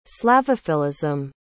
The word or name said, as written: slavophilism